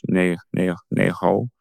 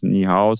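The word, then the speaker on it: Nei Hao (你好): Nei hao. Also im Kantonesischen heißt es nicht Ni Hao, sondern Nei Hao oder so.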